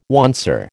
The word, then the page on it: oncer: American English